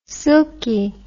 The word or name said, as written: silkie